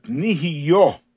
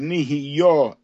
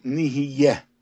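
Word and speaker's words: nih’yah (נִהְיָה): When we say the bracha Shehakol Nir Bidvaro, we pronounce it nih’yah hayud bekamatz. We say the word nih’yah and the yud should be with the kamatz vowel, veloy besegol, and not with the vowel of segol, which would say nih’yah.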